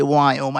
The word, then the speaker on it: Viome: So it's we as in Victor, I-O-M-E. So in case you're wondering when I say Viome, I really mean, you know, whatever that is.